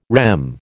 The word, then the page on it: REM: American English